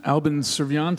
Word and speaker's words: Albin Serviant: Up next, we have Albin Servian.